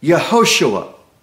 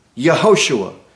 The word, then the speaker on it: Yehoshua: Joshua means, in Hebrew, the word is Yehoshua. Yehoshua. Yeho means God. Shua means save.